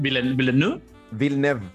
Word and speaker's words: Villeneuve: Mira, en palabras simples, porque me gusta más que la rechucha Villafrey o Villeneuve o Villanueva. ¿Cómo se dice en francés? – Villeneuve.